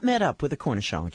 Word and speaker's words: cornichon: It's spelled C-O-R-N-I-C-H-O-N.